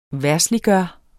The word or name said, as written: verdsliggøre